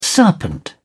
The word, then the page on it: serpent: British English